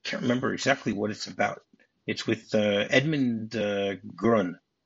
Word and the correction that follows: Gwenn: Gurn? I think that's how you pronounce his name. Edmund Gwenn, who played Santa Claus in Miracle on 34th Street.